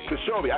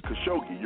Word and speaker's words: Khashoggi: Khashoggi. – I got it. Khashoggi.